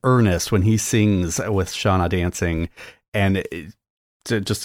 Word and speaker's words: Seona: So honorable mention to Ricky Gervais and his singing career before he started acting with Shauna Dancing. It's spelled S-E-O-N-A Dancing.